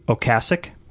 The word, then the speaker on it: Ocasek: Do you know that he pronounced his name Ocasek? – I did not know that.